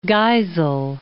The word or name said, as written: Geisel